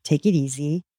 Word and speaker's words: Teikirisi: It's called Take It Easy, but it's spelled T E I K I R I S I. It's a Cuban slang word.